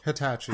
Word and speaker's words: Hitachi: Hitachi.